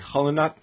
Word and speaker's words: Qallunaat: It's only on for 5 hours a day, but they had this documentary the other day called Kalanat, Q-A-L-L-U-N-A-A-T. – It actually is, it's pronounced like Kalanat.